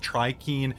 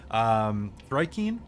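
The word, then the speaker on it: thri-kreen: In the meanwhile, as you look at the table, you see that a couple of trikeen, thrikeen is how they're pronounced, I think.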